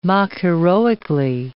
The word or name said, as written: mock-heroically